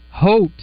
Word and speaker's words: Haute: First Arkansas Bank and Trust text line, it says it's pronounced hote, oh. – like it's close with a soft O or without a soft T.